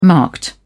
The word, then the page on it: marked: British English